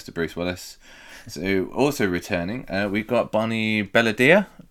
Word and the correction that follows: Bedelia: Did I say it right this time? Bedelia. There we go.